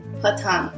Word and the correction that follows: poutine: I'm close. – Platon? – Poutine. – Poutine. – So if you guys ever go to Quebec, you have to say poutine.